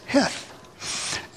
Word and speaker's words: Heth: The sea is silent there. It's Heth.